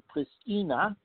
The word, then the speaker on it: Pristina: Talks collapsed last November when Pristina, they pronounce it, introduced a 100% tax on products made in Serbia.